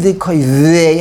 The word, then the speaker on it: v'koyvei: If you read it v'koyvei Hashem, you pronounce the vav, it's the vav, it's a part of the shoresh.